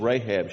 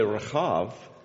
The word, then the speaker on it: Rahab: But the right way to say it, if you said Rahab, she wouldn't notice. But if you said Rahab, she would say, oh, that must be me.